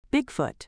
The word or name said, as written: Bigfoot